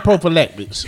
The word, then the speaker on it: prophylactics: And prophylactics. Did I say that right? – Yes, you did.